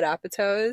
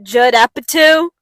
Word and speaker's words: Apatow: I had seen a post about something about, you know who Judd Appletoes is? – Yeah, why do you say his name weird? – Is that, Judd Apatow.